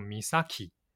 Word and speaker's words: 美咲: 它的日文发音叫做 Misaki 啊，所以我们今天的节目当中，为了方便大家来辨识哦，我们会用 Misaki 来称呼这一位失踪的女孩子。